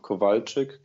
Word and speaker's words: Kowalczyk: It's Michael and um, Kowalczyk, K O W A L C Z Y K dot eu.